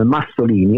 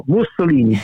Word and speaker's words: Mussolini: Dunque anche con gli insegnanti, io ricordo bene durante la scuola che correggevo il nostro insegnante di storia, per cui pronunciava la Mussolini come Massolini, e io dicevo no, Mussolini, brava, perché era un collegio cattolico tra i patriziani.